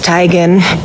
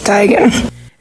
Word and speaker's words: Teigen: I'm tired of living this lie. It's Teigen. – What? – Isn't it, Mom? – Yeah. – Told you, it's Teigen. – It's Chrissy Teigen? – Chrissy Teigen.